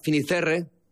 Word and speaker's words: Fisterra: Entonces llegó a Finisterre, ¿se dice así? – Fisterra. – Es como se ponen los gallegos también, en fin.